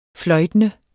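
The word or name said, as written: fløjtende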